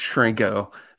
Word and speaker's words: Chrenko: So it's pronounced Shrinko. So you with a CH.